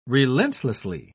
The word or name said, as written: relentlessly